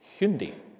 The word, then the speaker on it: Hyundai: And these things are far more valuable than a Hyundai, pronounced Hyundai, apparently.